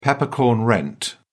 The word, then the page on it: peppercorn rent: British English